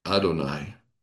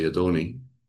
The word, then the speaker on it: Adonai: So the word Lord that's in lowercase in the English standard is the translation of the Hebrew word Adonai, or depending on some people say Adoni, because the. Sometimes the Hebrew doesn't have a long I, it's more like a YE. And so they say Adoni.